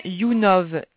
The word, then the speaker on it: YouNov: Point YouNov, YOUNOV. YouNov. – YOUNOV. YOUNOV. Voilà. YOUNOV, comme ça se prononce.